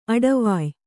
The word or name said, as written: ಅಡವಾಯ್